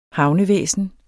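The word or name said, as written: VÆSNET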